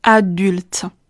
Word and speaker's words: adulte: 第二个难点在于发完了 du 之后，紧接着出现了两个辅音 lut lut，所以呢，我们要把 l 发的稍微轻微一些，迅速的滑到最后的 t 这个尾音上面。所以在发音的时候，du 这里要使劲的震动声带，最后的 l 和 t 要发的连贯，adulte。 adulte。